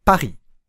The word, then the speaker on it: Paris: 先ほどと同じように、パにアクセントのままで、りはあまり意識しないでください。いきますよ。パリ、パリ、パリ。どうですかパリの発音に近づいてきませんかパにアクセントを置くことで、意識がパに集中するので、意外とリがうまく発音できるという逆転の発想です。